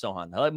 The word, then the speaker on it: Sochan: It is pronounced Sochan.